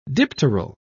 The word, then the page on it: dipteral: British English